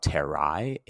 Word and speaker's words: terrae: So, for example, if we had the word like uh, earth, which is terra, terrae, aka terrae, that AE is pronounced I.